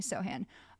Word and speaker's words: Sochan: And I also recently found out it's pronounced Sochan and not Sochan, which is I was going to say that's not how you say it.